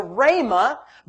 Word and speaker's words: Rhema: They're the same Greek word, which spells R-H-E-M-A, which is pronounced Rhema in the Greek, which if you study that meaning, it is the spoken word of God.